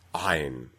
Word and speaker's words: Ayn: The name of it is pronounced Ayn. And that beginning sound of Ayn is essentially a pharyngeal, a voiced pharyngeal or epiglottic fricative.